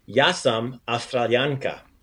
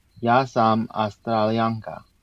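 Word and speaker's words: Ja sam Australijanka: Yasam Australianka. – Yasam Australianka. – Very cool. So just like I'm an American woman, Yasam Americanka, you have that A, that feminine A sound at the end, the A.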